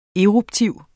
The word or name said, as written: eruptiv